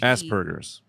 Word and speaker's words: Asperger's: It's like A-S-P. It's with a P. – Asperger's. – Not with a B. Not like ass. – Asperger's. – Yeah, Asperger's. – I mean, Asperger's, I know that this bit has been done a million times before.